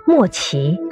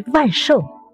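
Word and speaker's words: 万俟: 莫奇，常有人读为万寿；欧，常有人读为屈；贺。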